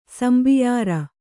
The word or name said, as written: ಸಂಬಿಯಾರ